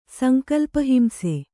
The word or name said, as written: ಸಂಕಲ್ಪಹಿಂಸೆ